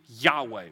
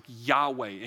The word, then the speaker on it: Yahweh: Anytime it's all caps Lord, it means the name Yahweh, which that's how we believe you pronounce it.